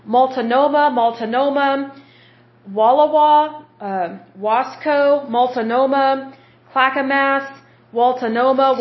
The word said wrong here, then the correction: Multnomah: Waltanoma, or sorry, Multnomah. Sorry, I'm getting my W's and my M's mixed up because these counties sound strange.